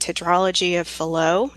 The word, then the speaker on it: tetralogy of Fallot: We also have critical congenital heart defects like tetralogy of Fallot. I'm not sure if I'm saying that right. I apologize. – You said that perfectly.